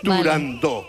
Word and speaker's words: Turandot: Para que quede claro, es turandot.